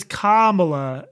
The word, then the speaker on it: Kamala: Okay, but while you were talking, I literally pulled up the pronunciation guide. Hold on. It says Kamala Harris. – Which is right. You said Kamala.